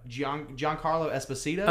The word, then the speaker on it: Giancarlo Esposito: Giancarlo Esposito? – Okay, yeah, Giancarlo, yeah.